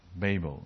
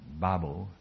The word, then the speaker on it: Babel: How you just pronounce it? Babel. Right? That's the Oxford standard. Some people pronounce it Babel. That's an American.